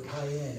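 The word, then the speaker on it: Cayenne (קין): That's what Cain or Cayenne is how you pronounce that in the Hebrew.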